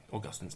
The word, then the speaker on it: Augustine: Am I pronouncing that correctly? – I guess that'll do. – Augustine. – Austin. Yeah, if you know anyone called Austin, it's a boiled down version of Augustine.